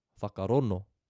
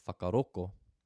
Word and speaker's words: whakarongo: They drop the G and just use N. So we have, instead of whakarongo, whakarono. The other dialectal variant in Te Reo Māori are the people of Southland, the Ngaitahu tribe, who don't pronounce the digraph NG. They replace that instead with a K. So instead of whakarongo, they have whakaroko. There are other dialectal differences within other tribes,